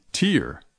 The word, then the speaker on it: tear: The answer is B, tear. Tear quiere decir lágrima. Tear quiere decir romper o rascar. Tear and tear, two very different words, but they are spelled the same way and pronounced differently as well.